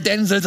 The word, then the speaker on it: Denzel: Darf jetzt wieder Denzel sagen. Weil es ist so, das ist sein Name. Er darf, er wird so ausgesprochen.